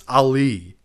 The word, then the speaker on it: Ali: It's Ali. – I'm pretty sure. Well, okay. I have it written down as Allie, which is why I keep saying that.